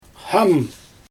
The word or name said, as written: xam